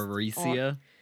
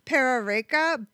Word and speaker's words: Perereca: Okay, it's P-E-R-E-R-E-C-A, so like I can't pronounce Pararecia. – Parareca, but I think the way her accent works, the R's sound like D's, so it was like the Perereca. is how it sounded.